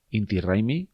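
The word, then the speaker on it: Inti Raymi: Es este Inti Raimi, eh, que es como se pronuncia en quechua.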